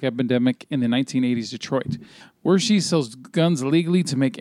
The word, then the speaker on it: Wershe: Wersch or Wershey? – It's Wershey.